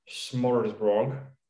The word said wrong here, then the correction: smorgasbord: You haven't butchered that at all. – Go on, tell me how it's pronounced. – Smorgasbord. – So I was nowhere near.